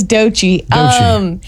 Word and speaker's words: Doechii: It says, thinking about the trip to Tuscaloosa, how about number 39 from last year, Anxiety by Doechii? Do you know this at all? – Yeah. – Am I saying that right? – Yes, Doechii.